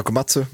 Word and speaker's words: Komatsu: I Umeå så står blodbussen idag vid, då ska vi se, heter det Komatsu? – Ja, Komatsu, absolut. – Komatsu, ja, vid Komatsu.